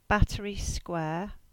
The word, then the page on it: Battery Square: English